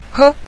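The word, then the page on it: he: tone 5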